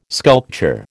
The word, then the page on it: sculpture: American English